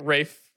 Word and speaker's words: Ralph Fiennes: and it also that doesn't look like how you should say his name, and it's fucked up. – Yeah, mm-hmm. Rafe.